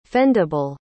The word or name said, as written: fendable